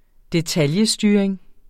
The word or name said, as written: detailstyring